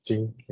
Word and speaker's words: Jun: It's Jun. Yeah. Same like the month.